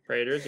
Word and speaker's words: Praetors: It has a bunch of oh my God, I can't pronounce it. Praetors in it. – Praetors, yep.